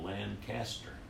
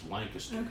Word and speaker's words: Lancaster: Not Lancaster. It's Lancaster. Happened to be spelled the same way, but it's like Houston and Houston.